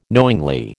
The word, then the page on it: knowingly: American English